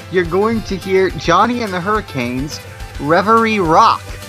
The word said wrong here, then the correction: Reveille: And it's pronounced Reveille.